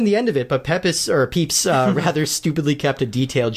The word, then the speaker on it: Pepys: Luckily, the writer Samuel Pepys, which is spelled P-E-P-Y-S, but I looked it up, it is pronounced Pepys, who has been a member of the Brotherhood for a few years, he learns of their plans and decides to stop them.